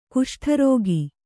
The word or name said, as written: ಕುಷ್ಠರೋಗಿ